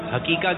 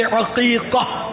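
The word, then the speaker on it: عقیقہ: اچھا, امیر السنت کی بارگاہ میں عرض ہے, کچھ لوگ حقیقہ کہتے ہیں, حقیقہ, اس کا صحیح تلفظ کیا ہے؟ – حقیقہ. – ہاں سے, عین سے, عین سے حقیقہ.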